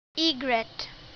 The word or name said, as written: egret